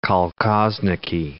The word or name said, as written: kolkhozniki